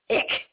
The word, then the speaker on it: Eich: They had a case, I believe his name is pronounced Eich, E-I-C-H.